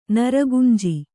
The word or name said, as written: ನರಗುಂಜಿ